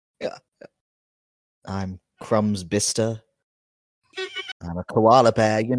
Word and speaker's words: Bicester: Bister, as in the town. – I've never heard of Bister. – It's spelled Bicester.